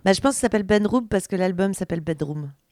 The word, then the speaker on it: bdrmm: Eh bien, on continue avec un groupe qui s'appelle Bedroom. Enfin, je devine qu'il s'appelle Bedroom, parce que du coup, ça s'écrit B-D-R-M-M.